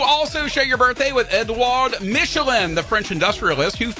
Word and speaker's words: Edouard: Edouard, the D is silent. Edouard. – Okay. – Edouard. You have to sound like someone's choking you with fingers.